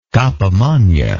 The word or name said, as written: cappa magna